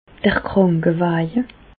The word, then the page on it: d'r Krànkewàje: Bas-Rhin; Reichshoffen